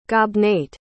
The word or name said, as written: gobnait